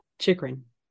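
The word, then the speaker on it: Chychrun: How do you say Jacob's name with the Yotes? Chicharin? – Chikrin. – Thank you.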